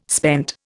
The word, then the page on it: spent: Indian